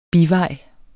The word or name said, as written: bivejs